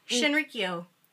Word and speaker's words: Shinrikyo: And even as recent as 1995, Arne Shinrico, I know I'm saying this wrong, I'm so sorry, which means supreme truth, which was a cult. – Shinrikyo.